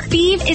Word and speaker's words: Fives: Fieve is spelled F-I-V-E-S, like the number, but pronounced Fieve.